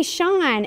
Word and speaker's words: Sean: And so I always say Sean, S-E-A-N.